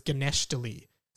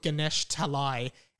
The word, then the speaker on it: Ganesh Talai: But when he was five, he was pronouncing it as Ganeshtali. So he was always looking for Ganeshtali, but it's actually called Ganesh Talai.